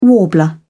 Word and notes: warbler: British English

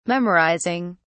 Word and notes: memorizing: British English

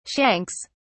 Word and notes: shanks: British English

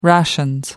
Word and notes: rations: British English

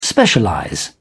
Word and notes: specialize: British English

